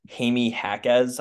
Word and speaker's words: Jaime Jaquez: I think it's Jaime Jaquez, I think is the way he pronounces it.